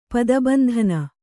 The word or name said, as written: ಪದಬಂಧನ